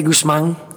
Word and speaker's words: Guzman: For our American friends, it is Guzman, yeah. We say Guzman, like, in the household, but it's Guzman, like, for...